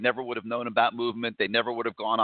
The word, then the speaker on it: MVMT: It's MVMT. That's how it's spelled. It's pronounced movement, but it's MVMT.